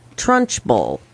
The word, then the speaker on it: Trunchbull: Trunchbull. I believe that I'm pronouncing that correctly. – Yeah.